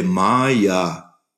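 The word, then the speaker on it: Maya (माया): Maya então, a pronúncia correta é Maya, porque vocês veem há um traço longo no A, tanto no Ma quanto no Ya.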